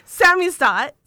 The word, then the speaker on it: samizdat: The Samzidant and the Tamzidant. Did I pronounce that correct? – Close. – Okay. – Now I'm all like, how do I say it? Samizdat, so which means basically self-publishing.